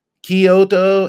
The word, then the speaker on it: Kyoto: And if I can float some pronunciation stuff out to people, Kyoto and Tokyo are actually Kyoto, Kyo, not Kyo, Kyo.